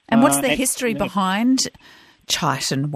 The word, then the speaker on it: Chiton: And then, even then, so the correct pronunciation is chitin, chitin whey. – And it's spelled C-H-I-T-O-N.